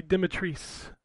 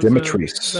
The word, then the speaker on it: Dimitrescu: Her name is pronounced Lady Dimitrescu. That was Dimitrescu. That's what the host of the RE direct said.